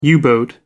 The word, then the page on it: u boat: American English